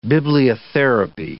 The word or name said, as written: bibliotherapy